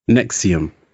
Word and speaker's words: NXIVM: which is spelt NXIVM and pronounced NXIVM.